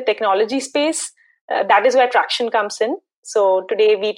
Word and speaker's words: Tracxn: I think I made a bit of a blunder on the pronunciation of Tracxn. Well, I was on the X Tracxn, but Tracxn. Got it.